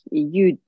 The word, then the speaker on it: Ljud: E, ilk projem e, İsveç'e gittikten sonra yaptığım e, şey U diye okunuyor. L, J, U, D diye yazılıyor.